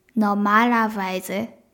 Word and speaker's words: normalerweise: normalerweise normalerweise です。まのまのまっていうところにアクセントがまず始めきます。 normalerweise で y のところでもちょっとアクセントが来るような感じです